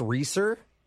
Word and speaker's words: Reeser: Yeah, my name is Alex Reeser. – Reeser. Can you spell that, please? – Yeah, R-E-E, S as in Sam, E-R.